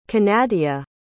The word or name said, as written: kenadia